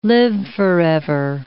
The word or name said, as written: live-forever